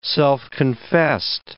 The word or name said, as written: self-confessed